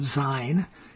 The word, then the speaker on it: Zion: And Zion, which is, you know, that's the correct pronunciation of Zion. It's called Zion.